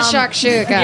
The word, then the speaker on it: shakshuka: Like, I ended up going with the omelet, and then we shared the shakshuka.